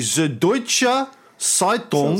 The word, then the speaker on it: Süddeutsche Zeitung: Uh, that's the uh, alliterative pronunciation that my German speaking wife gave me. Gave me Süddeutsche Zeitung.